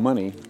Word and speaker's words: Money: I mean, money, just like it's spelled, M-O-N-E-Y, where Emmett Till was killed in 1955.